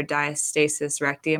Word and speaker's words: diastasis recti: She has very, very, very severe diastasis recti.